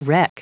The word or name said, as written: wreck